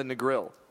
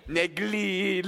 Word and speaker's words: Negril: Very, very, very good on the Negril. Because that could have been bad. – For a white boy like me, me have to say the L really hard. Really pronounce the L. – Negril.